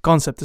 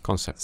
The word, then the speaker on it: Concept: Claro, Concept se llama, y ese lo pronuncié mal. Concept se Concept.